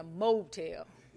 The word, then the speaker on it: motel: And I was in a little motel, and it's, when I say motel, I should put the accent on the right syllable. I was in a motel.